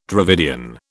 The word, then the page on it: Dravidian: British English